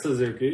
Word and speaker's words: Suzuki: Kurt Suzuki, yeah.